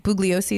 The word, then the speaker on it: Bugliosi: It's written by Vincent, I think Bugliosi is how you say it.